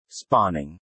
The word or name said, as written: spawning